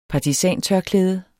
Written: snip

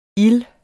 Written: ild

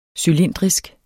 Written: cylindrisk

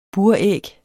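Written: BURÆG

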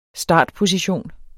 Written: startposition

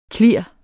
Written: klir

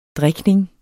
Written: drikning

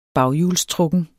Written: baghjulstrukken

